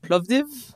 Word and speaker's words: Plovdiv: Plovdiv? – There you go. – Hey! – That was good. Well, you know, the Plovdiv is the sixth oldest living city in the world.